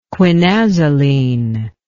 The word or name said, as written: quinazoline